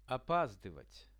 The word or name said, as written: opazdyvat